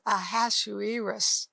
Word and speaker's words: Ahasuerus: There's a king named Ahasuerus, and I'm pronouncing it correct, because you say, are you pronouncing it right? Yes.